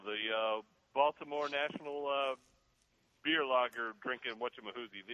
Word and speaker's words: Baltimore: It's Baltimore. Wait a minute. – Yeah. – No. Say it correctly. Balmer.